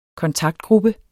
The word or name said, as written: kontaktgruppe